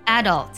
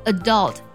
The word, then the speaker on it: adult: 成年人这个单词呢，有两种发音，英式的发音呢是读作 adult，美式的发音呢会读作 adult，两个都是对的。